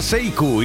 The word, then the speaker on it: Sekou: Se llama Seiku y si quieres localizarlo, que sepas que se escribe Sekou, así, con K y todo.